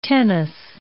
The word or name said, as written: tennis